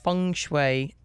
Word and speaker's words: feng shui: We're talking with Heather Melser, who is a certified feng shui consultant. I said that right? – Mm-hmm. Yep.